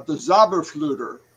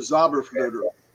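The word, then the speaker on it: Zauberflöte: If you remember, Mozart was a Mason, and Mozart in his last opera called the Magic Flute, which was the Zauberflüter, which that's how you say it in German, the Zauberflüter.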